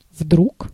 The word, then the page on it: soudain: [su.dɛ̃]; France